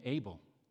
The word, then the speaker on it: Abel: That's because we normally pronounce his name Abel, Cain and Abel.